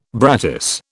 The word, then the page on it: brattice: British English